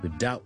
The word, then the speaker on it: doubt: 另外怀疑啦，doubt 啦，doubt 啦，doubtful 咁咧呢个怀疑咧，我哋嘅 B 亦都系唔需要读上，唔需要 doubt 咁样嘅。